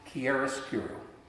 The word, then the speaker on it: chiaroscuro: It's pronounced chiaroscuro.